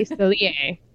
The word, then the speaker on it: Cellier: Yes, so we're going to do, we're going to start with the Four Kingdoms series, and that's by Melanie, her last name is spelled like Le Cellier, so I'm going to say Cellier, y'all, so there that is. – Yeah, it's C-E-L-L-I-E-R.